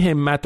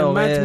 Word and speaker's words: همت: آقای مجتبی خاتمی هم یه کامنتی گذاشته بود در ادامه بحث این همت و همت، نوشته بود که اگه بخوایم درست عین حروف اختصاری رو تلفظ بکنیم باید بگیم همت، کسره زیر ه و کسره زیر میم، نه همت و همت و اینا که درست هم هست